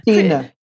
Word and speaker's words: Stina: How should I address you? Is, is, should it be Stein? How is the right way to pronounce Stina. it? Stina.